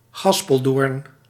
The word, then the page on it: gaspeldoorn: gas·pel·doorn